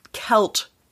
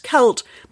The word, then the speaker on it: Celt: And so Celt is, there's no K in Gaelic. So the C's are all pronounced K. – Right. – So if you use the Gaelic pronunciation, but then it's Celt.